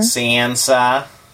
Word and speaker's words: Sansa: And then I thought it was Sansa. It's Sansa? Like a northerner? – Sansa. Hi, I'm Sansa. Sansa, so, Sansa.